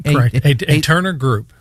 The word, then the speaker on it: Aeterna: All right, so Aeterna is what it's called. Did I pronounce that right? – Aeterna Group. – Aeterna Group, okay. Interesting name. It's spelled A-E-T-E-R-N-A, Aeterna.